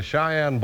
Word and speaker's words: Cheyenne: It's spelled C-H-E-Y-E-N-N-E, and it's pronounced Cheyenne.